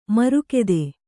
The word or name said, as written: ಮಾರಿಗುತ್ತ